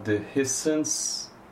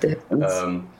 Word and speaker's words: Dehiscence: During the lockdown, you also released the, am I saying this right, Dehiscence? – Perfectly, yeah. – Perfect. – Dehiscence.